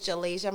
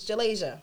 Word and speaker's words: Jalasia: My name is Jalasia. My Instagram is Jalasia Mayana, of course. It is not Jalisa. It's Jalasia. – Yes, so tell them. Say it right. – Please. It is Jalasia like Malaysia.